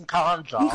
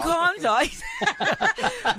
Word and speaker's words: Nkandla: Like, yes, we can now even pronounce Nkandla. – Nkandla.